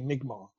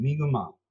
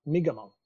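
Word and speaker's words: Mi'kmaw: not and uh, in our part of the world we we say we say migma. – Right. Uh, so there's the, the big thing to recognize there is there's two we're saying it incorrectly with two syllables. There's actually three. Migamau. Migamau. Right.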